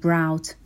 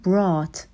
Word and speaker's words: brought: صدای آ داره، نمیگیم brought، میگیم brought. مثل فعل bought که گذشته buy هستش و اشتباه رایج تلفظیه که میگن bought، ولی شما این رو بدونین و این اشتباه رو نداشته باشین. میگیم buy، bought، bring، brought. هر دو صدای آ دارن.